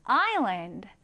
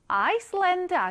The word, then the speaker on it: island: 이 단어를 딱 봤을 때는 아이슬란드 아니야? 라고 생각할 수 있는데요. 자, 여기 S가 있어서 그래요. 네, 이 S는요, 발음하지 않습니다. 묵음이에요, 묵음. 그래서 island, island 이렇게 발음을 해주는 것이 맞습니다. 자, 그러면 우리 이 단어 오른쪽에 같이 한번 써볼게요. island, I-S-L-A-N-D 읽어볼게요.